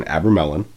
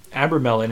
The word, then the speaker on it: Abramelin: I looked up the pronunciation. Abramelin. – Abramelin is the correct pronunciation.